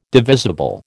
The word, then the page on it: divisible: American English